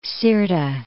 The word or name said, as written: Gulf of Sirte